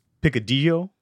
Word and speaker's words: picadillo: If you put out the two L's, it's a Y sound. – So say it? – D-O. – So say the whole word? – Like, if it's picadillo, then if it's, I think that's how you say it, yeah.